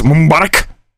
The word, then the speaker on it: Mubarak: Remember, if you want to be taken seriously in journalism, you got to pronounce it right. So it's Mubarak and Tahrir Square.